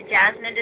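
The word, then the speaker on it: Jasmin: You can also search for me on Facebook by my first and last name, or you can look for Jasmine Design and Sign, the symbol, Photography, and you'll find my Facebook page.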